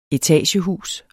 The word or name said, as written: etagehus